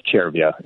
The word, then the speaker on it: Cervia: Cervia, but you spell it C-E-R-V-I-A, but it's, it's pronounced Cervia in Italy.